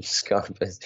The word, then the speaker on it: Schepis: Very Schepis, well played, right? Jacob Schepis. Schepis.